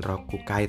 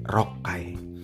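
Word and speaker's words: rokai (六階): Lantai 3 misalnya bukan sangkai tapi sanggai ada yang seperti itu lantai 6 bukan rokukai tapi rokai biar cepet ngomongnya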